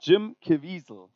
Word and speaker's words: Jim Caviezel: Das ist nicht Jesus, das ist nämlich der Herr Jim Caviezel. So spricht man den übrigens aus, Koray.